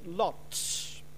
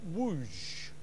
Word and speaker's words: Łódź: Just 150, including Mr. Hirsch, were sent to, we would probably say, Lodz, because it's L-O-D-Z. If you're Polish, you'd probably pronounce it more like Łódź.